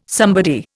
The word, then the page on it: somebody: Indian